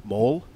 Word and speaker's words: Mål: How do you say like goal? How, you know, what's that in Norwegian? – Mål. – Mål? – Mål. – Mål? Okay. – Okay. – Spell that. – M and then it's A with a dot over. – Okay. – And then L.